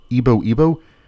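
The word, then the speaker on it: YBO²: The band's name is written YBO squared with the superscripted 2, but it's supposed to be pronounced Ibo.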